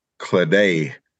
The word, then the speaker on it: Chladaigh: It's F with an A with an apostrophe A, I-N-N-E. – Fane. – And then yeah. – Cladaw is C-H-L-A-D-A-I-G-H. Claday. So this is like the how you would spell it in Irish.